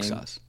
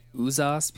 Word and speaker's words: Uxas: I always used to say Uzas because, like, sometimes with an X, like, it can be interpreted as a Z.